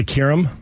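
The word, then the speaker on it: Qerim: Speaking of the NBA, Molly Karim on First Take. – Her name would be Molly Kirim.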